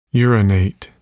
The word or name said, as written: urinate